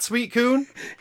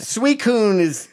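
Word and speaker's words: Suicune: It's not sweet coon? – It's Suicoon? I mean, they say Suicoon. – Suicoon is what it is, but the way that it's spelled looks very much like suicide.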